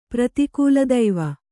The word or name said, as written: ಪ್ರತಿಕೂಲದೈವ